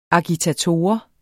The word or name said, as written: agitator